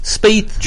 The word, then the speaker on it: Spieth: How much money did Mr. Is it Spieth? Is that the way you pronounce it? – Spieth. – Spieth.